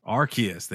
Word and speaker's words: Arceus: But number one was Pokemon Legends Arceus. Arceus.